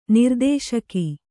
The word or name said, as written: ನಿರ್ದೇಶಕ